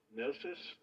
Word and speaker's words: Mneseus: But that first one, M N E S E U S, phonetically is pronounced almost identical to K-N-O-S-S-O-S, which is the name of the Minoan capital on Crete.